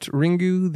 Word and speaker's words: Ringu: We are talking about Ringu, the Japanese original of the ring.